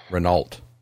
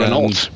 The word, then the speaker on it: Renault: household, the entire time my father alive, was never pronounced Renault. It's pronounced Renault. And Renault.